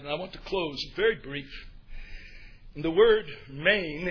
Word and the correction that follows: mien: M-I-E-N, it's pronounced mean. I'm sorry, not main, but mean.